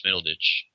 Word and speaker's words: Middleditch: led by Thomas Middleditch. Is that how you pronounce it? – Yes, I could not place him, but that's, of course, from Silicon Valley.